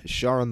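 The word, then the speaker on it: Charon: Sharon? – Sharon the Boatman.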